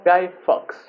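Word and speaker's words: Guy Fawkes: B de Venganza, que a su vez es de eh, Gay Fox, no sé si está bien pronunciado. Guy Fox. Guy, Eh, este señor era parte de la conspiración de la pólvora en 1605.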